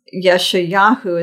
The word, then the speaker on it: Yeshayahu: And before I go on, I want to also mention the name Isaiah, which is a combination of the word for salvation, Yeshayahu is the way you pronounce it in Hebrew.